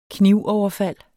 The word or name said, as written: knivoverfald